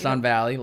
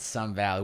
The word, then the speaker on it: Sun Valley: Sun Valley. We gotta say it right. – It's not Sunland? – It's Sun Valley.